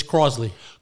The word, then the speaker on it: Crosley: You're doing a case on do you pronounce it Crosley Green? – It's Crosley.